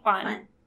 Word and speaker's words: Hwan: H-W-A-N. Hwan. – Seolji. G? – Seolji Hwan.